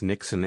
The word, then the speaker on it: niksen: And the one that I found, I know it's going to sound like I'm saying Nixon like the president, but it's not. It's Nixon, N-I-K-S-E-N.